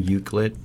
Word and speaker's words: Euclid: And that's in, I can't even say the name, Euclid. – Yep. – Shoot, I said it right. – You did.